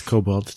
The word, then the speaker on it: kobold: Well, if it's spelled K-O-B-O-L-D, it would be kobold.